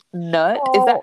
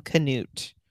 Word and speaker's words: Knut: It's funny that you say that though, because that was like one of the things, like she kept being like nut. Is that how you pronounce her husband's name? – So K-N-U-T. That's how it's spelled. In the audio, they called him Canute.